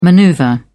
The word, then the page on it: manoeuvre: British English